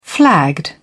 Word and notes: flagged: British English

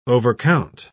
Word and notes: overcount: British English

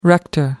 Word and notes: rector: American English

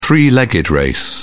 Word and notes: three legged race: British English

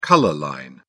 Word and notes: color line: British English

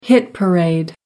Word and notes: hit parade: American English

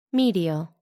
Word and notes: medial: American English

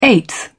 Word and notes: eighth: British English

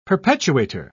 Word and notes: perpetuator: British English

